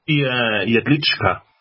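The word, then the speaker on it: Jedlička: How do you pronounce the president's name there over in Liberland? – That would be Jedlicka. Vit Jedlicka. – You don't pronounce the V in V-I-T?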